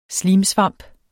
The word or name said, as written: slimsvamp